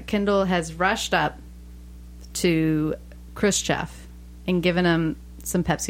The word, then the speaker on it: Khrushchev: I really don't want to say his name wrong, but I'm confusing it with Gorbachev, so I'm, like, trying to... – Khrushchev. – Look at the letters. – Christoph.